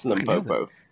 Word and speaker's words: Limpopo: Limpopo? Sure I didn't say that right. – Yes, Limpopo.